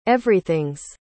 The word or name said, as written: everything's